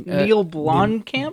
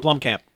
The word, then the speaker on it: Blomkamp: Neil Blomkamp? – Blomkamp, yeah.